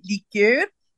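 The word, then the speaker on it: likeur: assez semblable, c'est liqueur, liqueur,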